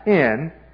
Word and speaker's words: hen: It's heis, but it is a different gender. It's pronounced hen.